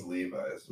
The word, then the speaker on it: Levi's: That's because, earlier this week, a pair of, what is it, Levi? Is that how you pronounce it? – I think it's Levi's. – It's Levi's. I don't know why I'm saying Levi.